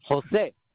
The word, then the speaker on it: Jose: Jose. – J-O-S-E? – Yes.